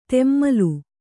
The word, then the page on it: ತೆಮ್ಮಲು: temmalu